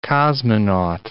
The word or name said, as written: cosmonaut